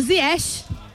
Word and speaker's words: Ziyech: A gente tem a pronúncia Ziyech, marroquina.